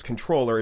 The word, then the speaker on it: comptroller: You know, I looked up, I always wondered why it was controller, even though it's spelled comptroller.